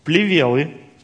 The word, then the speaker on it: плевелы: Пришел враг его и посеял между пшеницей плевелы или плевелы и ушел. Итак, мы видим второго сеятеля. Знаете, вот это слово плевелы или плевелы, как будем называть, плевелы или плевелы? Плевелы. На «е» ставить ударение.